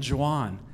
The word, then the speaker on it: Juan: Hey, I'm Juan. – Juan? – No, it's Italian. It's spelled like Juan. It's spelled like Juan, but you pronounce it J because it's Italian.